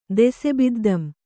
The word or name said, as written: ದೆಸೆಬಿದ್ದಂ